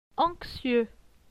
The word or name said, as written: Anxieux